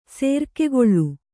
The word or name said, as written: ಸರಕುಗೊಳ್ಳು